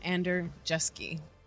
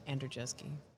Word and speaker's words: Andrzejewski: Vivid's Chief Safety Officer, and today I'm joined by Tom Andrzejewski, who is the Safety Director with Hunt Electric Corporation.